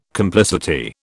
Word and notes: complicity: British English

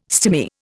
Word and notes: stimy: Indian

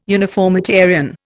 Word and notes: uniformitarian: Indian

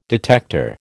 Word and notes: detector: American English

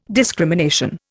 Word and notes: discrimination: Indian

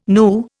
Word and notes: nu: Indian

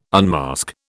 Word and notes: unmask: British English

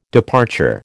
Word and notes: departure: American English

